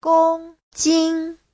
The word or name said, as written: gong1jin1